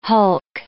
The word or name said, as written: hulk